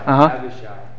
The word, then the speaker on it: Abishai: Shibadai? – Abishai? – Abishai.